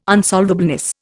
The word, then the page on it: unsolvableness: Indian